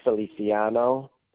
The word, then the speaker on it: Feliciano: It's spelled R-A-U-L. And the last name is Feliciano. And that's spelled F-E-L-I-C-I-A-N-O.